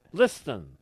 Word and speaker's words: listen: Listen. – Listen. – You don't pronounce the T in listen.